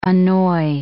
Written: annoy

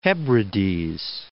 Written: New Hebrides

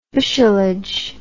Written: bushelage

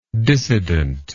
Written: dissidently

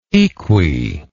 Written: equi

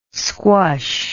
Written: squush

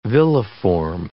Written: villiform

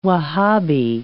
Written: Wahhabi

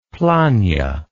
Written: planh

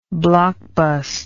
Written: blockbust

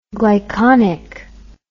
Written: glyconic

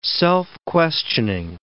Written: self-questioning

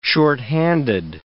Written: shorthanded

